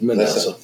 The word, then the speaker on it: Manasseh: Manasseh. Is that how you pronounce it? – Manasseh.